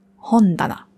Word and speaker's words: Hondana: Aber nur wenn es alleine steht, dann spricht man es Ta aus. Steht es zusammen in Kombination mit dem Hon, dann ändert sich die Aussprache auf Da. Hondana.